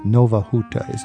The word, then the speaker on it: Nowa Huta: Nowa Huta. Am I saying that correctly, Beata? – That is correct.